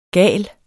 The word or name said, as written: gal